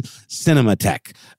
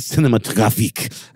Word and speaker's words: Cinematheque: I pronounced it totally wrong. It's the American Cinematheque, not Cinematographique. I did not need to go quite as French with that.